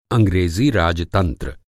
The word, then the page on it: राजतंत्र: Hindi